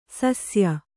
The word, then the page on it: ಶಸ್ಯ: śasya